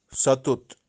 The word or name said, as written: Satut